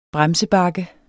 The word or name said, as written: bremsebakke